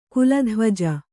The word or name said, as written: ಕುಲಧ್ವಜ